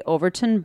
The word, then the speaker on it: Overtoun: The Overton Bridge, and I hope I'm pronouncing that right. It's spelled O-V-E-R-T-O-U-N. – Overton Bridge? Overton Bridge? – Just say Overton.